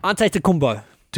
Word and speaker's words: Antetokounmpo: Okay, Giannis Antetokounmpo. – Antetokounmpo.